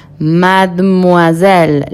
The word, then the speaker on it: Mademoiselle: Mademoiselle, la E que viene después de la D, no se pronuncia.